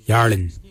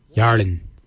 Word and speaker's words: yarrliny: is your skin, Yarlin. It's spelt Y-A-R-R-L-I-N-Y, Yarlin.